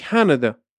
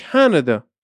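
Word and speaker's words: Canada: میگیم کانادا سه تا حرف آ داره توش که هر سه تاشون هم یه شکل تلفظ میشن خب اما یه دونش تاکید بیشتری روشه یعنی تاکید روی سیلابل اوله کانادا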